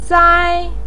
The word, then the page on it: sai1: pinyin